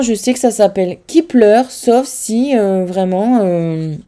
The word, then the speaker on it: Kep1er: Et en fait, c'est Kipler et pas Keep One Ear ou Keep One Ear, c'est Kipler.